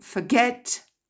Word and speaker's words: forget: To forget, I forgot, forgotten. To forgive, I forgave, forgiven. Donc, ces trois verbes qui commencent par F-O-R, l'accent tonique est en deuxième position. Il n'est pas sur le F-O-R.